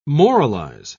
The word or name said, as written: moralize